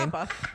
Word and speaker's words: ABBA: ABBA.